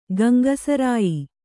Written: ಗಂಗಸರಾಯಿ

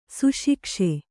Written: ಸುಶಿಕ್ಷೆ

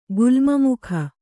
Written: ಗುಲ್ಮಮುಖ

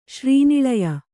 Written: ಶ್ರೀನಿಳಯ

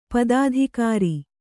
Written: ಪದಾಧಿಕಾರಿ

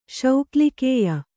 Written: ಶೌಕ್ಲಿಕೇಯ

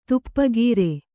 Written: ತಪಕೀರ

